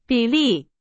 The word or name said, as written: 比例